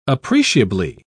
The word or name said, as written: appreciably